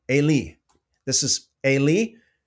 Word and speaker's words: Eli: It's not Eli, but let me play it for you. – Strong's H, 5941, Ailey, Ailey. – Okay, so according to the Strong's Strong's gentleman there that pronounces these names, it's Ailey, Ailey.